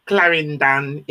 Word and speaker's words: Clarendon: They're from Clarendon in Jamaica. That's the way you pronounce it. – Clarendon.